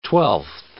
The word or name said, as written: twelfth